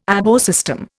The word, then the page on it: ABO system: Indian